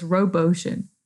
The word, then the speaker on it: RoboOcean: So it's RoboOcean. So like robot and ocean.